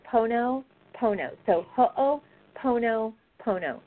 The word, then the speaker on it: Ho'oponopono: I'm sorry, how do you say that again? – Ho'oponopono.